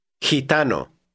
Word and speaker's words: gitano: The same is true of the combination G-I. Read word 7. – Gitano.